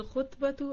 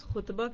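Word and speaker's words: خطبة: अगर आप खुतबा कहें तो वही है जो आपके सामने है, अगर आप इसको खितबा कह दें तो क्या होगा? जी, मंगनी को कहते हैं। खातिब, खिताब, मुखातिब, मुखातब, यानी मंगनी भी एक किस्म की किसी से बातचीत होती है, उसमें आप खिताब करते हैं और दूसरा बंदा उसका जवाब देता है। तो प्रोनाउंसिएशन दुरुस्त होना चाहिए हमारा।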